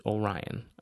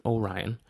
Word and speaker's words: Orion: So that is the proper pronunciation, is Orion, according to a few movies and video and clips I had seen from NASA's site. I had only really done reading online. But yes, so that's the proper pronunciation, Orion, like the constellation Orion's Belt, all those things.